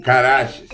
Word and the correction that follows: Caraxes: Segundo velhos tomos em alto valeriano, a correta pronúncia do dragão do Daemon é Caraxes.